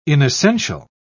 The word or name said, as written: inessential